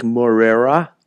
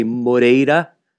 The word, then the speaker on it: Moreira: If it's R, it's just, you know, you're either going to pronounce it like Moreira, or we say Moreira.